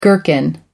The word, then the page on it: gherkin: American English